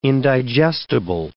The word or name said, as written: indigestible